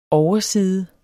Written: Oversiderne

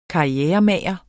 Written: karrieremager

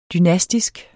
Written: dynastisk

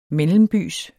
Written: mellembys

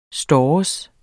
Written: stores

